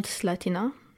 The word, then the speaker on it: Slatina: You are you're born in Zlatina. I am right in your pronunciation. – Yes, it's called Slatina.